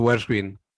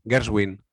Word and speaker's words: Gershwin: George Gershwin. ¿Lo he dicho bien? Gershwin. – Sí, muy bien.